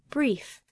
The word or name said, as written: brief